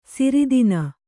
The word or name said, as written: ಸಿರಿದಿನ